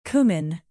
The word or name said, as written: cumin